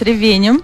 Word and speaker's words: ревенем: Так сильно меняется ударение в слове в зависимости от падежа, но мы все-таки попробуем. Дело в том, что сегодня отмечается день пирога с так. ревенем. – Да. – С ревенем. Правильно, с ревенем.